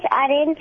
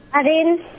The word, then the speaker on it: Arin: My name is Arin. And the answer is Bindi. Okra. – Okra. – What's your name again? – Arin. – How do you spell it? – A-R-I-N.